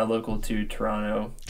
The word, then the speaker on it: Toronto: Don't say Toronto. It's Toronto. There's no T.